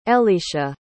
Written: ellecia

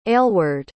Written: aylward

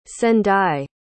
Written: sendai

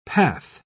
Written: path

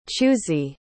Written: chu'si

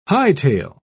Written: hightail

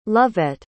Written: lovette

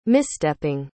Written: misstepping

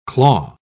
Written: claw

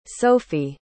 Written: sofie

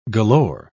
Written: galore

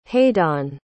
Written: hadeon

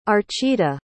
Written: archita

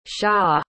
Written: siya

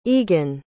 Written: eagon

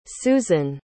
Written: suzann

